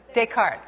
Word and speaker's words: Descartes: René Descartes, I don't know how to say his name, Descartes? How do you say it? Descartes.